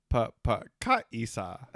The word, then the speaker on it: pagkakaisa: Pagkakaisa. Is that correct? – Well, you are missing one more syllable. – What is it? – It's pagkakaisa. – Pagkakaisa.